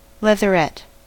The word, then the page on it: leatherette: American English